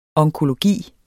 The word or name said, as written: onkologi